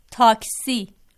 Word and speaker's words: تاکسی: اور تلفظ جو ہے ٹیکسی کا کیا ہے؟ – تاکسی.